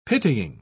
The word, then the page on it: pitying: British English